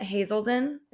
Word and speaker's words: Hazelden: And you said something about your time at Hazelden.